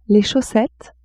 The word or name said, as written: chaussettes